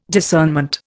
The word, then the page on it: discernment: Indian